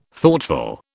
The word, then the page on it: Thoughtful: British English